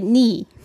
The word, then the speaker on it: 네: So how do you pronounce it correctly? – It should be 네.